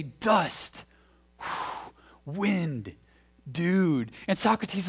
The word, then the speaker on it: Socrates: They can't say his name right. It's Socrates.